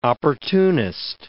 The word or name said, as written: opportunist